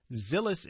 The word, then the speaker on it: Xilis: Xilis Incorporated, that's spelled X-I-L-I-S, Xilis Incorporated's microorganosphere technology generates thousands of miniature tumors derived from patients that can be analyzed in their own microenvironment.